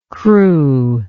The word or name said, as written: cru